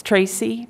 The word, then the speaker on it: Tracy: Yes, Tracy Baker, T-R-A-C-Y-B-A-K-E-R.